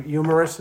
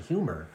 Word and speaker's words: humor: And why are you pronouncing it like that? – What do you mean? – Humorous. – Well, I thought they were funny. – Well, no, but you're saying like there's a silent H in humor.